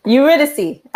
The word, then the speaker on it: Eurydice: Eurydice.